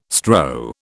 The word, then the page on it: strow: British English